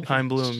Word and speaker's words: Chaim Bloom: Chaim Bloom.